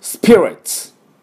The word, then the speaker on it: spirits: もちろん焼酎も、入ります。ですから焼酎のことをね、Japanese Spirits というふうに私は言っておりますが、はい、では皆さんこの発音ね、ダブルシーンですね、スプスプスプスプスプで、レッツ、 TS を同時に出します、ツツツですね、ちょっと舌を軽く前の方に持ってって、そのまま S、ツ、この音です。では私の後について発音いきましょう、スピリッツ、スピリッツ、完璧です。